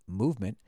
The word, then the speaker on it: MVMT: Uh, with fair prices, unexpected colors, and clean original designs, MVMT, pronounced movement, grew into one of the fastest growing watch brands, shipping to over 160 countries across the globe, globe, globe, globe.